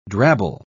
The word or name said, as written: drabble